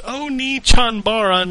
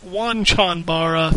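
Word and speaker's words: Onechanbara: But he says it's pronounced Oni Chanbara, not Wan Chanbara. – Thanks. Yes, mm, that's what I've been saying. – Okay, I've heard it pronounced both ways, and from not just American people. So I will defer. I don't claim to know, and also it's not Cham, it's Chan.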